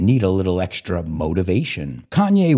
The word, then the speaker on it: Kanye: It's not Kane. I think a lot of people say Kane. It's Kanye. Kanye West.